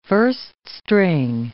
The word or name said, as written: first-string